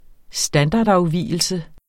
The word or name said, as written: standardafvigelse